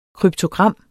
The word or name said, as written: kryptogram